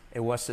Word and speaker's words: it was: It was a truly tough time back in 2020 it was, fijaos que no digo it was, sino it, la T casi no se dice y como es, it was.